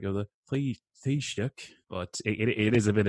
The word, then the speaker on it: Taoiseach: I would say, I think of like a cup of tea and then shock. Like, oh, I just got shocked by that Taoiseach.